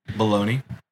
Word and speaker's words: bologna: Baloney. – Baloney. – It's spelled Bologna. – Bologna. Yeah, it is weird.